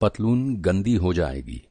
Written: पतलून

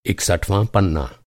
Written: इकसठवाँ

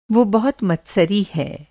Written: मत्सरी

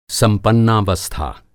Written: संपन्नावस्था

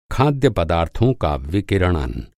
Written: विकिरणन

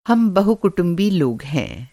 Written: बहुकुटुंबी

